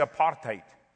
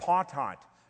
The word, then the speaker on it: apartheid: It's actually apartheid, which is actually an Afrikaans word. It's not even an English word. You're not even pronouncing it correctly. Hello. Oh, you got apartheid.